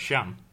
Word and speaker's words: Can: Oh, how do you say it? Shan. Emre Can.